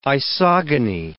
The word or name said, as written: isogony